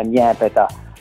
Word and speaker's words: Nepeta: Eh, in realtà è nepeta, eh, il, eh, in siciliano dalle nostre parti detta niepeta ed ecco anche perché l'accento sulla prima a volte.